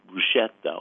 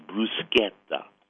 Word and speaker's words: bruschetta: Now, I know it's mispronounced even on TV, which really irks me, that I feel like a TV announcer, especially one doing maybe an Italian travelogue, should know how to pronounce the word. But you hear the mispronounced bruschetta all the time. It is bruschetta.